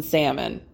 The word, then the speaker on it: salmon: who thinks that the L needs to be pronounced in salmon.